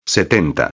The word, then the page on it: 70: espanhol